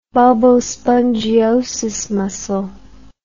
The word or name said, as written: bulbospongiosus muscle